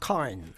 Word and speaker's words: Cairn: Ben, c'est-à-dire qu'en Australie, la ville existe et là-bas, ils prononcent « Cairn ». – Cairn. OK.